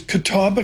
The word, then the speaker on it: Catawba: A zillion acres, Catawba County.